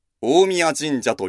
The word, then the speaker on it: 大神神社: 大きい神の神社と書いて、大神神社ではなく、大宮神社と読むのは、古来、三輪の大物主の神は神の中の神であり、神といえば直ちに三輪の大物主の神を指したためと思われます。